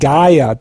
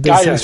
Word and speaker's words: Gaia: Gaia, das heißt, das Gaia, schreibt sich G-A-I-A, so wie die Erde auf Griechisch. Nicht, dass jemand nach Geiern sucht.